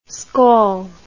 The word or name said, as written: skol